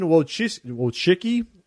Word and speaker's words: Wojcicki: So Susan Wojcicki, is how you say it, the YouTube CEO, said that he didn't deserve to be banned from the platform despite his crude actions.